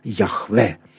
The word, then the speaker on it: Яхве: Оно только учеными, только ученые установили, что оно звучало «Яхве», то есть вот как бы «Бог с нами», да?